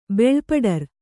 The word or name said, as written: ಬೆಳ್ಪಡರು